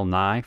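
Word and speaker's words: Nighy: It's spelled differently. It's N-I-G-H-Y.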